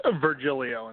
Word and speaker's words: Virgilio: Virgilio, and actually you said okay.